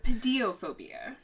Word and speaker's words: pediophobia: Pedophobia. – Pedophobia? – Pedophobia. – Pretty sure it's phobia. – Whatever. – It's pedophobia. P-E-D-I-O-P-H-O-B-I-A. Pedophobia.